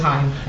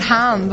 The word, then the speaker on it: Haim: It's, well, Haim, I met them and they said it was Haim.